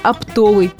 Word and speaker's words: оптовый: Вся хитрость словечка в том, что оно является производным от существительного «опт». А у этого существительного во всех формах ударение падает на первый слог. Ну, например, «купить оптом». Поэтому интуитивно может возникнуть желание поставить ударение по аналогии и допустить ошибку. Все словари единодушны. Правильный вариант – оптовый.